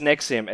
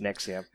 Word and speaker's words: NXIVM: It's pronounced Nexium. It's spelt NXIVM. But it's pronounced Nexium.